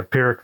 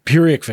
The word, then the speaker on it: Pyrrhic: it doesn't feel like even if it's a I don't know how to pronounce this, a Pyrrhic victory or whatever. – Yeah, Pyrrhic victory. – Pyrrhic victory. There we go.